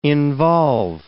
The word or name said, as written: involve